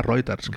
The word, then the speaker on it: Reuters: Reuters, es pronuncia Reuters. Crec.